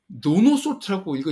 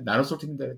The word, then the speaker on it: 나노솔트: 노노 솔트라고 읽으신 분이 계셔가지고 NIH에서 저희한테 진짜로 지금도 그 어워드 레터가 있는데 어, 노노솔트와 노노솔트 팀 이렇게 해서 컨그레츄레이션을 해서 왔었어요. 그러니 노노솔트가 아니라 나노솔트입니다.